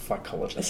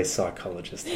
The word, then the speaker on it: phycologist: That's correct. Phycologist. – So it's P-H-Y. – Should be clear with the pronunciation. – Yes. – It's very, people do want to say psychologist all the time.